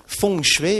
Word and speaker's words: feng shui: Elle est faite, je la prononce ici en chinoise, elle est faite de deux mots chinois. Feng, c'est le vent, F-E-N-G, transcription, et shui, c'est le mot que nous avions vu tout à l'heure, qui désigne l'eau, S-H-U-I.